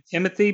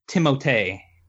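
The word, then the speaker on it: Timothée: We all call him Timothy, but I was reading on IMDb here. It's supposed to be pronounced Timotei.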